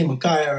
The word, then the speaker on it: Micaiah: I believe most people would say Micaiah, right? But my wife gets on me because we say Isaiah. You see what I say? You see the suffix A-I-A-H?